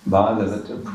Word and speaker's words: Bhagavata: It's called the Bhagavata Purana because it's about Bhagavatas and about the activities of Bhagavatas. So there's the word Bhagavan, and then a Bhagavata, with a long A, is anyone related to Bhagavan.